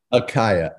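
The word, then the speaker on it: Ekhaya: Akaya.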